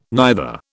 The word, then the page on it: neither: British English